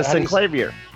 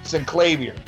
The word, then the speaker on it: Synclavier: The Zinclair? – The Synclavier. – Synclavier.